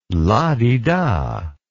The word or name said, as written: lah-di-dah